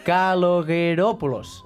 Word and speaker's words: Kalogeropoulos: Ho he de llegir a poc a poc, sí? – L'altre dia amb la pronúncia txeca, sí, avui amb la pronúncia grega. – Però he estudiat, eh? Si dic a poc ho puc dir, i torno, val? Kalogeropoulos, eh?